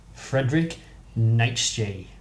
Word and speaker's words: Friedrich Nietzsche: Friedrich Nietzsche. – I'm intrigued to see if that's the right way of pronouncing that. – That is definitely how you pronounce his name.